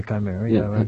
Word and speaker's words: Chimera: You won the National Book Award for Chimera. Am I pronouncing that correctly? – I would say Chimera.